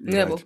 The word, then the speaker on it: Ngcebo: Okay, so it's NGCEBO. How do I pronounce that? – Nebo. – Yeah. Okay, I'm correct.